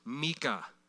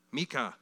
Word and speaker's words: Micah: Micah, pronounced Mika. Mika.